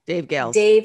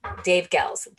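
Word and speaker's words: Goelz: Richard Hunt, and I'm going to say this name wrong. – Dave Gels. – Dave Gels. Thank you.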